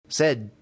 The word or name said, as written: sed